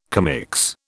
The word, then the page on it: commix: British English